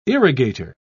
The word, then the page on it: irrigator: British English